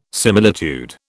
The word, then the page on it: similitude: British English